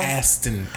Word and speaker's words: Aston: Ashton Martin Music. How you say it? Did I say it wrong? – Aston. Yeah, there's no H there. – Oh, that's true. Aston.